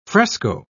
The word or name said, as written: fresco